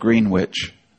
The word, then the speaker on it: Greenwich: It's spelt Greenwich.